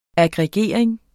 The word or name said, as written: aggregering